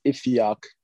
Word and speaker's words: Ifiok: So if you can't pronounce Ifeok, which is spelled I-F-I-O-K, you could just call me Ife, I-F-F-Y.